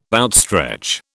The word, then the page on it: outstretch: British English